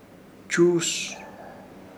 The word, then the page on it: Chuus: Ishir Chamacoco